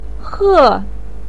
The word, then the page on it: he4: pinyin